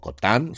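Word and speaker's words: Cottin: Cotin, o Cotan, si queréis la pronunciación francesa, ha tenido que dejar el proyecto por problemas de agenda.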